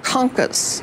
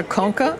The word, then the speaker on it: concha: And on one I saw conca, and one I saw concha. – Concha. It's like okay. – Shell. It's a shell. Gotcha. Concha means shell.